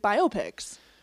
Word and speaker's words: biopic: I want to know some of your favorite music biopics. – So, okay, yeah, we did look up the pronunciation. It is biopic, apparently.